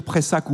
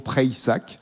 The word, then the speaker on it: Preissac: Alors, je crois qu'on peut dire Preissac ou Preissac, si on veut prononcer un peu à l'occitane. Je sais qu'on tient beaucoup à cette prononciation. On dit donc plutôt Preissac, en accentuant un peu sur le Y.